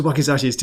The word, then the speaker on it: wakizashi: Welcome to Wakizashi's Tea House, pronounced wakizashi, which is the shorter sword of the two swords held by the samurai in the past.